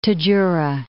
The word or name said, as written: Tadjoura, Gulf of